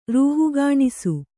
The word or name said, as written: ರೂಹುಗಾಣಿಸು